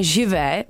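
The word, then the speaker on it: Je veux: Vyslovuju to správně? Aha, na mě teda kývá, že živé. – Ano. – Živ. – Řekni nám, jak se to správně vyslovuje? – Pojď na mikrofon. – Živ.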